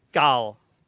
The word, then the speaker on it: Cathal: Your name is spelled C-A-T-H-A-L, but your name is pronounced Gal.